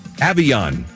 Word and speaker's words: Avion: How about Avion, 26. He's 6'6". – I'm out. – Hold on. – How do you spell it? A-V-I-A-N? – A-V-I-O-N. – Oh, so it's not like avian flu.